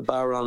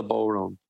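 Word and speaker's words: bodhrán: The Baron is the drum you play, not the Boron. The Baron.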